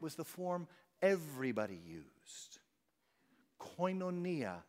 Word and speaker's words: Koinonia: Koinonia is how you say it. Koinonia.